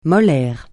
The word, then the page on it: molaire: francais